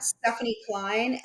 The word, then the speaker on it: Stephene Klein: It's at Stephene Klein. And my name is spelled a little bit uniquely. My name is spelled S-T-E-P-H-E-N-E.